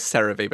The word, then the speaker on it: CeraVe: Yes, it is CeraVe, but that was pure guesswork.